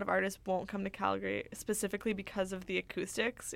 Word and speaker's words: Calgary: There's an A, so you pronounce it Calgary.